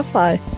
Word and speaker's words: Phi: Segundo a JBC, quando se escreve alguma coisa com PHI, se pronuncia Fai.